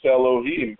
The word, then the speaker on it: Elohim: Whenever we see it in the Torah or the Tanakh scrolls, the second Havaya is pronounced Elohim by the reader.